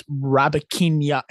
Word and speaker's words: Rybakina: It's Rybakina.